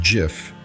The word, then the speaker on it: GIF: She would later point out that the acronym GIF is pronounced JIF, noting I pronounced it wrong in the song.